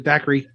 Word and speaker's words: Dacre: d-a-c-r-e dacre is that how you say it that's how I've always said it. Dacry. Dacry. Yeah. – Okay. Dacry. Dacry. Oh, Dacry.